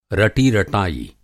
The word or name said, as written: रटी-रटायी